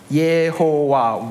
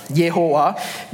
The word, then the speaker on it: Yehovah: Okay, you can see that you might notice that the first vowel shifts from an A to an E sound, okay, because of a standard rule of Hebrew pronunciation. Okay, and this hybrid form, Yehovah, became common in some old English translation and also some of our Chinese translation today, Yehovah. Okay, however, this hybrid form arose by accident. Okay, and most Bible scholars agree that it does not represent the original pronunciation of the divine name.